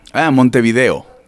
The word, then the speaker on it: Montevideo: Montevideo.